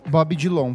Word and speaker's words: Bob Dylan: Bob Dylan, por favor. Vamos pronunciar o nome dele corretamente aqui.